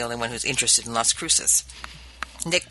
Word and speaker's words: Las Cruces: You're mispronouncing Las Cruces. – It's Las Cruces. Anyway, my ex-husband is from Las Cruces.